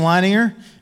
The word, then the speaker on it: Leininger: I'm Pastor Jason Leininger, and I say my last name so that you can hear me say it.